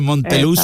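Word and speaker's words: Montelux: Claro, la confusión un poco por la pronunciación de la Z, ¿no?, en Andalucía, pero el nombre comercial es Monteluz, exacto, finalizado en X, efectivamente.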